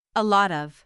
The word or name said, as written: a lot of